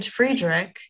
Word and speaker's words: Friedrich: My duck is still named Friedrich, which I'll have you know, I watched The Sound of Music last night, and Friedrich is the correct pronunciation.